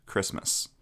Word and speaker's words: Kiritimati: As such, an island spelled Kiritimati is pronounced Christmas.